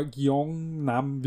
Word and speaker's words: Gyeongnam: Aber Gyeongnam wird so ziemlich hinkommen, schätze ich. – Klingt gut.